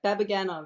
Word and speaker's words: Babaganov: Babaganov, yeah.